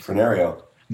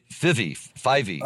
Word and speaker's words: Fyvie: I guess it's a traditional Irish ballad originally called The Bonnie Lass of, maybe you can pronounce it, F-Y-V-I-E. – Fenario. – No, this is the Bonnie Lass of Fivey. – Oh, really? It's like Brett Favre.